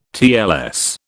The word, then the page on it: TLS: British English